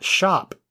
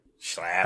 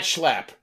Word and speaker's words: Schlapp: Matt Schaap. I left the L out. Schlop. – Matt Schlapp? – Sure. – Schlapp.